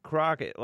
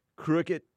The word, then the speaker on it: Crockett: What do I say? – Crooked. – What? – Crooked. – Oh. And what is it? – Crockett. – What the? Crockett, cricket, crooked, potato, potato.